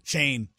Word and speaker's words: Schoen: What did I say? – Schoen. – How do you pronounce it? – Shane. Joe Schoen.